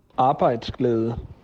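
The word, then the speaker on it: arbejdsglæde: Vamos escutar esta palavra impronunciável para mim que sou brasileiro. Arbeitsglädje. Então, a, a pronúncia Arbeitsglädje, algo assim.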